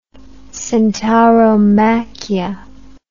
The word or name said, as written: centauromachia